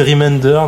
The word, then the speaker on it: Remender: Donc c'est Remender, voilà, donc c'est le parrain de l'émission apparemment.